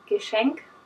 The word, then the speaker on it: Geschenk: For example, in my region where I come from, people use wrong grammar, first of all, and they also, like, any word that begins with a G, which is pronounced G, becomes Y. – Oh, interesting. – So, for example, the word Geschenk means present, and they say Geschenk.